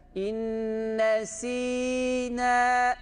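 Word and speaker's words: İnne sina (إِنْ نَسِينَا): İnne sina deniyor. Şu met harfi okunmuyor. Biz burada met harfini okuyacağız. İnne sina. İnne sina diye burayı çekiyoruz.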